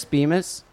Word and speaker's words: Bemis: that said Harris Beamer. No, not Harris Beamer. – Bemis. – Harris Bemis rhymes with phallic penis.